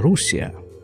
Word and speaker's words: Русия: Да, на иврите Россия называется Русия. Съездила в Русию, как это звучит на иврите, читаю с ударением на первый слог.